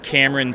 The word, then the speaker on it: Camron: And my first name is spelled C-A-M-R-O-N. And then you can also find me on Instagram, and that's at Cameron T.